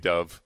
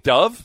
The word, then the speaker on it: Dov: D-O-V-E? D-O-V. Yeah, D-O-V. Yeah, Dove Kleiman. – Oh, Dove. Oh, Dove Kleiman. Okay, I know him. You pronounce it Dove? – I pronounced it Dove. I don't care how he pronounces it. D-O-V. It's Dove.